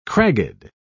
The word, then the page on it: cragged: British English